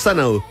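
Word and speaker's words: Xanadu: Palabras con X. Exacto. o la pronuncias bien y haces el ridículo, o la pronuncias mal y pasas piola. Así que le vamos a decir Sanadu.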